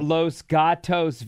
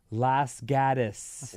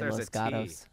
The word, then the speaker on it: Los Gatos: Not Los Gatos. Say it like a native. Las Gatos. – What? – Las Gatos. – That doesn't sound like Los Angeles. Las Gatos. I say Los Gatos.